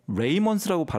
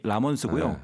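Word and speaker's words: Ramones: 그래서 76년에 오늘 소개하는 이 보통 이제 R-A-M-O-N-E-S라서 레이먼스라고 발음하시는 분들이 종종 계신데 본래 이름이 라몬스고요.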